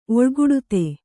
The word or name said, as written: ಒಳ್ಗುಡುತೆ